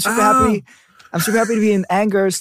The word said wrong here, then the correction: Angers: today. But it's like everyone's like, no, it's Angers.